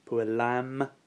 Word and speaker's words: puella: The first declension, puella, nominative, puellam, accusative, puellae, genitive, puellae, dative, and puella, note the long A, ablative.